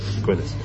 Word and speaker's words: Aquinas: So that's I think why at some point, I forget who it was, but Thomas, not the train, you know, the is it Aquinas, is that how you say it? Thomas Aquinas. Yeah, that's how you say it. Aquinas.